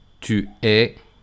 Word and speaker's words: tu es: Por ejemplo, tu e il se pronuncian igual. Tu es, il est, esas S y la T no suenan.